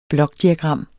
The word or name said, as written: blokdiagram